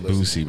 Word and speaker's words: Boosie: Bro, did that nigga not say Bootsie? – I said Boosie, bro.